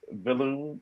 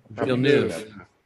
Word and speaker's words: Villeneuve: Dennis Villeneuve, how do you say his name? – Villeneuve.